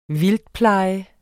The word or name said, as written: vildtpleje